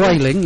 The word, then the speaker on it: Vueling: Okay, Vueling, if I pronounce that correctly. – Vueling, yes.